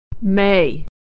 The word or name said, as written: may